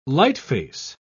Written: lightface

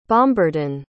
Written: bombardon

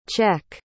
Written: cech

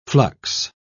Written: flux

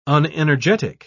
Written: unenergetic

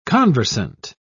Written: conversant

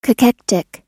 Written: cachectic

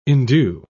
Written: endue

